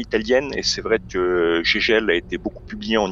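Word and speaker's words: Žeželj: Euh, et puis dessiné par un de mes chouchous qui est Daniel Gégel et non pas Zezelge comme je le vois parfois prononcé et je suis un petit peu à cheval sur ce genre de choses donc euh, Daniel Gégel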